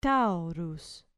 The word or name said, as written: taurus